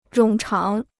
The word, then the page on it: 冗长: rǒng cháng